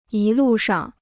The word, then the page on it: 一路上: yī lù shàng